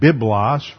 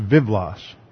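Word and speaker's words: vivlos (βίβλος): So every time you see be like we have the word biblos, from which we get our word Bible. That would be pronounced vivlos.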